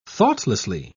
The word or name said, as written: thoughtlessly